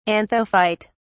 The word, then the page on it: anthophyte: British English